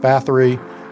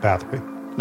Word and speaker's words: Bathory: Now, we are going to be using the English pronunciation of her name, which is Elizabeth Bathory, much to the chagrin and anguish of my co-host, Jesse.